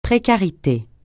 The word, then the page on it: précarité: francais